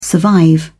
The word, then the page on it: survive: British English